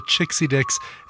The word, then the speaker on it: Chixie Dicks: Yes, that is the correct pronunciation, the Chixie Dicks, and their cover of Bandada's Like a Virgin.